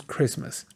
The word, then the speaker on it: Christmas: Aunque en escrito dice Christmas, bueno, Christmas, porque es una CH, la T no la decimos. Y estoy hablando del inglés norteamericano. Incluso en el inglés británico, en la mayoría, no van a pronunciar la T. La T, por conveniencia, básicamente, para que se oiga bonito y puedas hablar de corridito, evitamos la T. Entonces decimos Christmas.